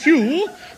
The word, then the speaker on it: Pugh: N-E-D-D-I-E. Neddy? What was next? – Neddy Pugh Seagoon. – Pugh? P-H-E-W? – No. It's pronounced Pugh, but it's spelt Pug. – Pug, yes. P-U-G-H.